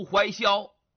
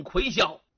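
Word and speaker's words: 隗嚣: 韦敖这个人名啊，好多人读错，有的人山东秀才认办了，读怀萧，其实不对呀，那个字啊是一个大耳旁一个鬼，那个字俩读音，一个念魁，一个念伟，所以有人呢也把它读成魁萧。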